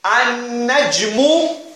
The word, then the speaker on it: An-Najmu: In An-Najmu, you did not pronounce the Lam. Yes or no? You join directly with the Noon. And you put a Shadda there. Huh? It even sounds wrong if you said Al-Najmu.